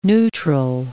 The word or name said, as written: neutral